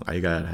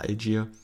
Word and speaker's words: Allgeier: Deswegen ist die Frage, ob da Tyler Allgeier, also heißt auf Deutsch ausgesprochen Allgeier, heißt eigentlich Tyler Algier, oder auch BJ Robinson so viel Laufeinheiten bekommen werden oder so viel, so viel Attempts zum Rushen.